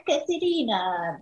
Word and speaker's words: Caterina: Sometimes people are like, "Well, what's your name? Is it Caterina or Caterina?"